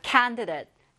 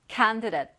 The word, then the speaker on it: candidate: 자, candidate, candidate or candidate. 둘다 가능해요. 이 DAT를 the이라고 발음하셔도 괜찮고요. 여기 지금 우리 교재에 나온 것처럼 date라고 발음하셔도 괜찮습니다.